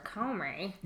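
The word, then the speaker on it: Comey: And then about a week ago, Mr. Comrie. – What's he called? Is that how you say it? I've been wondering about how to pronounce it. – That's the correct pronunciation, Mr. Comrie of the FBI.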